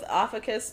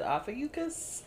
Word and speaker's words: Ophiuchus: So I was going with Ophicus before, but I actually saw a pronunciation on an article that said Ophiuchus. And so I'm like, okay, are we pronouncing the U? Okay, we're pronouncing the U. So we're pronouncing the U, y'all.